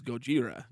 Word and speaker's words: Gojira: It was the same thing with, like, I mean, you know, Godzilla is Gojira. You know what I mean? So it's G-O-J-I-R-A.